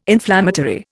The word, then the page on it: inflammatory: Indian